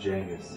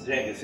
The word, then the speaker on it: Genghis: When people pronounce Genghis Genghis? – Yeah. – It's actually supposed to be pronounced Genghis. – Genghis, yeah.